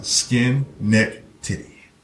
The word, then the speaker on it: Schenectady: And I said, Schenectady. – I said, how you pronounce it? Skin, neck, titty.